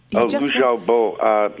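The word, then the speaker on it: Liu Xiaobo: And please help me with the pronunciation. He won Lu Xiaobo. Yes, one of the characters in the novel.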